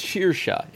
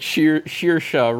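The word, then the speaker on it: Saoirse: It's Shearsha. It's something like that. It's, like, not how it's spelled at all. It's like Shearsha Ronan, I believe is how you pronounce her name.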